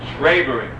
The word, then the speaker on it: traibering: And the word that's used for this quote is traibering. Traibering, I guess the way it's spelled is T-R-A-I-B-E-R-I-N-G.